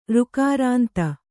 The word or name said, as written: ಋಕಾರಾಂತ